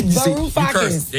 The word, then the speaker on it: Varoufakis: Varoufakis.